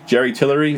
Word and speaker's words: Jerry Tillery: Jerry Tillery? Is that how you say it? – Yep.